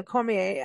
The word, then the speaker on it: Cormier: Now, you know, you've got your wrestler, you know, Daniel Cormier, and I'm not pronouncing that right, I know.